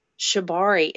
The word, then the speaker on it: shibari: Shibari, am I saying that right? Shibari? – Yeah.